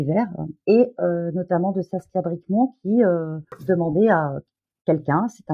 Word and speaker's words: Saskia Bricmont: Saskia Bricmont. – voilà, Saskia Bricmont.